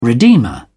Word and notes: redeemer: British English

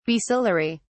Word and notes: basilary: British English

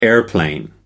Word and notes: airplane: American English

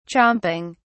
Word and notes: chomping: British English

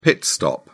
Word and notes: pit stop: British English

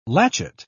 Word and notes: latchet: British English

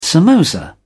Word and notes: samosa: British English

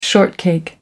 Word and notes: shortcake: American English

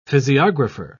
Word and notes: physiographer: British English

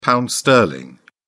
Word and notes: pound sterling: British English

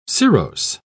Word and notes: cirrose: British English

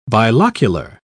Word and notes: bilocular: British English

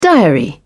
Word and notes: diary: British English

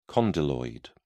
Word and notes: condyloid: British English

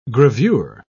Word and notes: gravure: British English

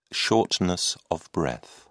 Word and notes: shortness of breath: British English

